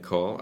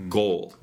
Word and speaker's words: call: call 사실 들어보세요. 네. – call. – 이거는 long o 아니잖아요. 네. 장음 o 아니에요. call. 그래서 한국어 어 하고 더 비슷해요. – 맞아요. – call이라고 하면은 예를 들어서 goal 놓았어요. 그거는 장음인 거 맞아요.